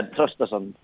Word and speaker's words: Traustason: Yeah, Traustason.